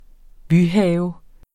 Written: byhave

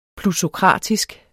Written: plutokratisk

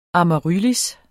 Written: amaryllis

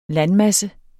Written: landmasse

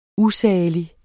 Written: USALIGT

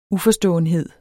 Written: uforståenhed

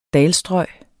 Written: dalstrøg

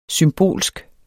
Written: SYMBOLSKE

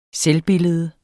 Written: selvbillede

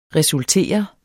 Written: resultere

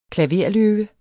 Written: klaverløve

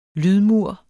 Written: lydmur

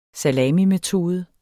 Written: SALAMIMETODEN

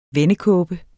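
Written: vendekåbe

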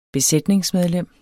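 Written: besætningsmedlem

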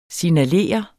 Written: signalere

